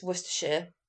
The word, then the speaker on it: Worcestershire: But it's Worcestershire.